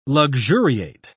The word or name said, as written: luxuriate